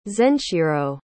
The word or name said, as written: zenshiro